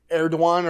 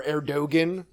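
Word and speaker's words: Erdoğan: They've asked, Erdogan or Erdogan, if you want to read it phonetically, has asked, like, Trump to send Enes Kanter extradite him to Turkey to go on trial.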